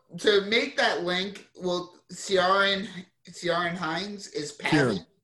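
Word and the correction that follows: Ciarán: Kieran is the proper pronunciation.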